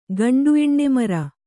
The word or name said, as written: ಗಂಡುಎಣ್ಣೆಮರ